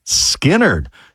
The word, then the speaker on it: Skynyrd: It was on this day in 1973, Lynyrd Skynyrd released their debut album, pronounced Skynyrd.